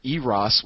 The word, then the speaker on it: Eros: The other problem is that I have is that it's very close to Eros or Eros, which is how it's supposed to be pronounced, which is a fairly large potato-shaped asteroid which passes near the Earth every now and again.